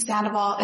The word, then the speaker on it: Sandoval: Sarayel, S-A-R-I-E-L, and Sandoval is S-A-N-D-O-V-A-L.